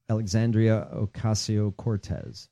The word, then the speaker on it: Alexandria Ocasio-Cortez: Alexandria Ocasio-Cortez.